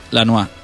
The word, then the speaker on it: Lanois: Dani Lanois. ¿Está bien pronunciado en francés? Lanois. Poco a poco. – Merci Lanois.